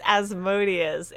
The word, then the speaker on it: Asmodeus: Ozymandias? What is Ozymandias? – No, it's pronounced Asmodeus.